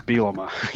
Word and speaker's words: Bielema: We had a picture of a bee and a picture of a llama, and you put them together and it's Bielema. So there was all kinds of things we were trying to get for people to phonetically understand how to say his name.